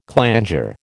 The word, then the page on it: clanger: American English